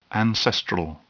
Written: ancestral

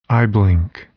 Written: eyeblink

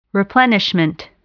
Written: replenishment

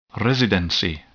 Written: residency

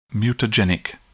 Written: mutagenic

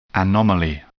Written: anomaly

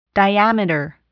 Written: diameter

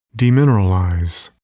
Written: demineralize

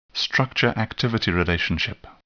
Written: structure-activity relationship

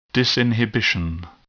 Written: disinhibition

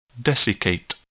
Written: desiccate

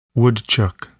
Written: woodchuck

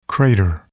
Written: crater

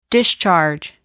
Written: discharge

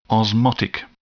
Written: osmotic

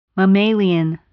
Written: mammalian

